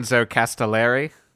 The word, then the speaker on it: Enzo Castellari: Enzo Castellari.